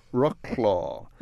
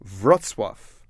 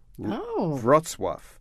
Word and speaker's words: Wrocław: Rocklaw. But anyway, it is pronounced sort of like this. Wrocław. Wrocław.